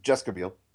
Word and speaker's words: Jessica Biel: You're Jessica Biel.